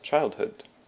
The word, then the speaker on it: childhood: And actually, that is our next word, is childhood. Childhood. And childhood has also the emphasis on the first syllable, the child. Childhood.